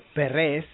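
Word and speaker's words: Pérez: There you have Eric Perez, or Eric Perez, or Perez in Spanish, as we like to pronounce.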